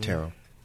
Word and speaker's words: tarot: So examples of divination in a sentence is the fortune teller was known for her divination skills using tea leaves and tarot cards. – Mm-hmm. Tarot. – Tarot cards. The tea is silent.